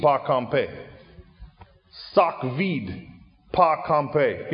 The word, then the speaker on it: kanpe: We would spell it V-E-E-D. They spell it V-I-D. Sock vide. Pa. Like papa, only one. Pa. This is a little harder. Compay. K-A-N-P-E.